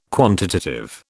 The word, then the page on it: quantitative: British English